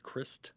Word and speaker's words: Krist: It's famously Kurt Cobain, who is lead singer and guitar, Krist Novoselic, and that's how you say it, Krist, who played bass, and Dave Grohl on drums.